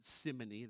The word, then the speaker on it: simony: Have you ever heard the term simony? Some pronounce it simony. That's a secondary way to pronounce it.